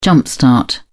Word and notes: jump-start: British English

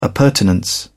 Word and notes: appurtenance: British English

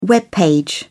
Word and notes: web page: British English

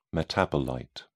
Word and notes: metabolite: British English

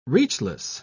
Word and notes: reachless: British English

